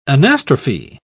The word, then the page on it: anastrophe: British English